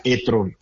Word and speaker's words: e-tron: Realmente Audi lo pronuncia e-tron, como se pronuncia en Alemania, Paco. No lo pronuncia e-tron como sería en inglés. Lo pronuncia e-tron, que me parece que el alemán se traduce a e-tron.